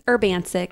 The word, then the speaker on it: Urbancic: Dani, how do you say your last name? – Urbancic. – Urbancic, okay.